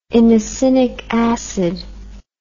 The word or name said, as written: inosinic acid